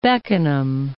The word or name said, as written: Beckenham